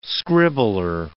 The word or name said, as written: scribbler